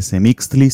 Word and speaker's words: Semixtli: Eh, Semixtli sí se pronuncia así, está en Nahualt y significa la nube.